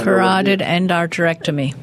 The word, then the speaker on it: carotid endarterectomy: You probably carotid endarterectomy.